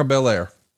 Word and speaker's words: Belair: I mean, you know, B- Bianca Bianca Blair is that her last name? – Yeah, Bianca, Bianca Belair. Belair.